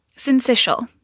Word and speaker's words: syncytial: And I hope you're going to tell me how to pronounce syncytial. – Syncytial. Yep, syncytial. – You got it.